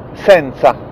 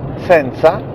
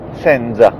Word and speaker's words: senza: Quindi potete ripetere senza, dopo di me, senza, attenzione perché senza ha la lettera Z, quindi si dice senza in questo caso e non si dice senza.